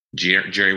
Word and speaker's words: Gerry: Gerry with a G, and then McGoldrick, which is my rap name, McGoldrick.